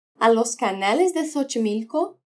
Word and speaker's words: Xochimilco: Y por fin suena como S al principio de una palabra, como en Xochimilco. Habla Xochimilco. Además de la pronunciación hemos visto que reírse de y reírse con tienen sentidos muy distintos.